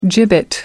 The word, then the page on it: gibbet: American English